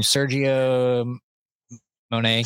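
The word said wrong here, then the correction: Serge Monast: Sergey Monast.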